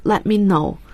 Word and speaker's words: let me know: 要注意 let me know，注意他的表达，T 是不发音的。